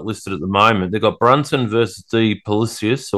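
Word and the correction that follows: Du Plessis: or whatever they're pronouncing it. – Duplessis, yep. – Duplessis, sorry, yep.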